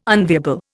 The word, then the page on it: unviable: Indian